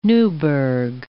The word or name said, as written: Newburgh